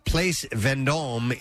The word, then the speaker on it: Place Vendôme: A group of thieves have struck the Bulgari jewelry store on the Place Vendôme in Paris. How do you say place in French? Is it place? – Place Vendôme.